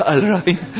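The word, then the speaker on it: Al-Rahim (الرحيم): This Al-Rahim, is it correct?" Al-Rahim means the all merciful one. "Yes, it's correct, Al-Rahim. The way you say is very good pronunciation."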